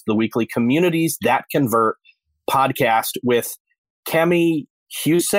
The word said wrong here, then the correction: Huse: I hope I said that right. – Huysa. – Cami Huysa.